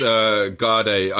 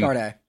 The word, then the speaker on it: Garda: A bunch of hitmen dressed like Irish Guardia. Am I saying that right? Guardia? It's like the Irish police force? – It's Garda. I mean, Garda. Just you can just say guards.